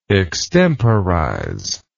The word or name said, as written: extemporizer